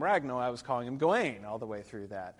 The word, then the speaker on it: Gawain: But here, this is alliterative verse, and the emphasis is on the first syllable, Gawain. He's clearly not Gawain, because the emphasis, the alliteration, is usually on the G, so that's what's clearly supposed to be emphasized.